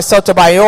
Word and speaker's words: Sotomayor: And some other knucklehead said that by calling her Sotomayor, I'm putting the accent on the last syllable.